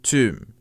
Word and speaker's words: tomb: 嗯，也就是墓的意思。那注意，刚刚说了它的拼写是 T O M B，但是呢，we don't pronounce the B at the end.